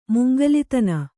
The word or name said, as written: ಮುಂಗಲಿತನ